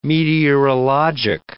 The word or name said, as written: meteorologic